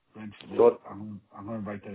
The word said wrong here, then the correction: Renfrew: No, Renfrew. Renfrew. – Ren. You'll have to spell that for me, Stuart, if you don't mind. – R-E-N. – Yeah. – F-R-E-W.